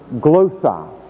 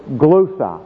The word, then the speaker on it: glossa: Well, the word tongue is the translation of the Greek word glossa, G-L-O, long O, S-S-A, glossa.